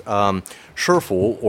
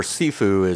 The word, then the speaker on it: Sifu: Shifu, or Sifu, as it's, you know, more commonly pronounced in the Cantonese, the first half of that, Si or Shir, comes from Lao Shir or Lo Si, which means teacher, literally, or old teacher.